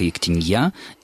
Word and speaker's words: ектенья: А вот Роман нам уже успел написать, что в Брагаузе пишется «Ектение» с ударением на 2-й слог. – А вот в словаре Резниченко, который рекомендован Министерством образования как один из 4 базовых словарей русского языка, говорится, что это только «Ектенья», и, опять же, фиксированы оба написания и через «и», и через мягкий знак.